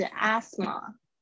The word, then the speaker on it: asthma: 那这边有一个 s，t h，t h 这边不发音。那这边呢，我要跟告诉大家，就是英式发音是发 s，美式发音是发 z，所以看你比较喜欢哪一种声音，有可能是 asthma 或者是 asthma，就是很轻微的差别。